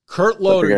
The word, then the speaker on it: Kurt Loder: Is it Kurt Loder? – Kurt Loder.